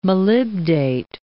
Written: molybdate

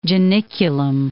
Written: Janiculum

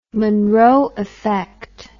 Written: Munroe effect

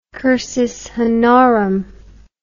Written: cursus honorum

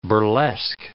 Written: burlesque

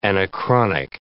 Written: anachronic

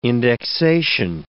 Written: indexation

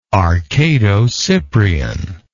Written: arcado-cyprian

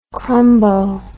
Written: crumbo

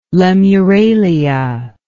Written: lemuralia

